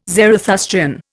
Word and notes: Zarathustrian: Indian

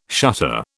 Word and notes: shutter: British English

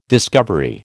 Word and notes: discovery: American English

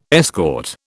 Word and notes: escort: British English

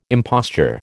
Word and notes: imposture: American English

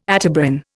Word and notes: Atebrin: Indian

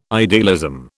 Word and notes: idealism: British English

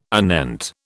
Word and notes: anent: British English

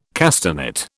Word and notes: castanet: British English

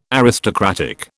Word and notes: aristocratic: British English

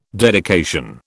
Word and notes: dedication: British English

